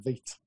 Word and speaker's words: Vite: It's pronounced Vite.